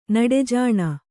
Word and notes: ನಡೆಜಾಣ: naḍe jāṇa

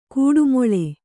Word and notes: ಕೊಟ್ಟೆಮುಳ್ಳು: koṭṭe muḷḷu; Kannada

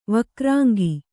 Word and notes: ವಕ್ರಾಂಗಿ: vakrāngi

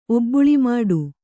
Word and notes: ಒಬ್ಬುಳಿಮಾಡು: Kannada

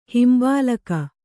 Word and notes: ಹಿಂಬಾಲಕ: himbālala; Kannada

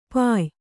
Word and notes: ಪಾಯ: Kannada

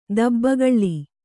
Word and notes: ದಬ್ಬಗಳ್ಳಿ: Kannada

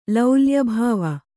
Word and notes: ಲೌಲ್ಯಭಾವ: kannada

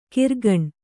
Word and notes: ಕರಗಾಣ: karagāṇa

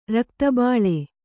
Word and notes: ರಕ್ತಬೋಳ: kannada

